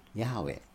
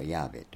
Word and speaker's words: ヤハウェ: で、この初めの神っていうのはエロヒムというですね、一般的な神という言葉ですが、この主という言葉は本来ヤハウェという神の名前なんですけども、読み方が本来神聖だから、聖なるものだからって読まないでおいた、まあ、そういうとこから読み方がずっとわからなかったんだけども、その他いろいろな研究からこうしたことがヤハウェと、あるいはヤーベと読むということはまあ分かってきたわけです。